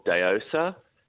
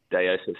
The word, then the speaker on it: Daosa: So number two is called Deosa, and it's out of Adelaide Hills. – Can you spell that, please? – Deosa, so D-A-O-S-A.